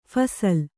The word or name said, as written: ಫಸಲು